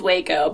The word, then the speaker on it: Waco: I can never get it right. What is the right way to say it? – It is Waco, but people... – Waco? I got it right the first time? – Yeah, okay.